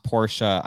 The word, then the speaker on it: Porsche: Tyler said it's pronounced Porsche. I know it is. I just never say it right.